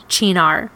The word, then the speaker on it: Cynar: So when you go to look for Chinar in the store, you're not going to find it easily because it's not spelled Chinar. It's Italian. It's C-Y-N-A-R. So you'll often hear people call it Cynar. And although that's not correct, oftentimes I will pronounce it like that just so you understand.